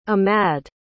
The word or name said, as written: amad